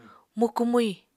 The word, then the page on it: mukú-mwi: Apatani